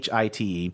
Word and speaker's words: Hite: I'm gonna guess it's Shere Hate. Is that how you pronounce it? – Yeah, pronounce it. But Shere Hate, Hite.